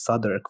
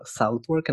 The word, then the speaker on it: Southwark: And so Bankside is in a place which, like, I know now is pronounced Southwark, but at the time I thought it was Southwark.